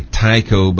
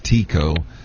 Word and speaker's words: Tycho: That was the, I say Tycho, but I think it's really, Jerome, I think it's really supposed to be pronounced Tycho, crater.